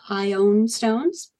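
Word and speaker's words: Ioun stones: And if I'm pronouncing it funny to your ears, it's because John Vance says that's how his dad pronounced it. So they're ion stones.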